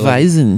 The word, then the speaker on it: Hefeweizen: How do you say that? – Hefeweizen. – Like, Hefeweizen. With no American accent.